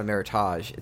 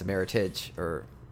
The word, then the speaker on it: Meritage: It's not a Meritage. It's a Meritage. Yeah, Meritage, because it's an English word.